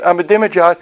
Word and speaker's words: Ahmadinejad: If you don't know how to say his name, Ahmadinejad, say Ahmadinejad very fast, very quickly.